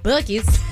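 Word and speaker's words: Buc-ee's: Bucky's.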